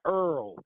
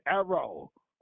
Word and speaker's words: Errol: And his name is not Earl. His name is Errol. It's two syllables.